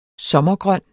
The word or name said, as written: sommergrøn